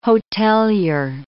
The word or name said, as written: hotelier